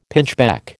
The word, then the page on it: pinchbeck: American English